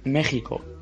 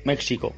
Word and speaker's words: México: Por eso decimos también México. Y no, México.